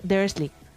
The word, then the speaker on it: Dursley: Se escribe Dursley y se dice Dursley.